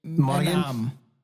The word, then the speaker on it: Monaghan: Mangane. – Where are we? – Monaghan. – Oh, Monaghan. – Monaghan. Okay, so you do the Okay, that's the super soft G.